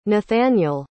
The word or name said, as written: nathanael